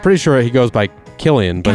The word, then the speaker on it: Cillian: And by the way, you spell it C-I-L-L-I-A-N. – It is Killian.